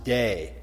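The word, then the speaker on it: day (δεῖ): That's the little short Greek word that's pronounced day.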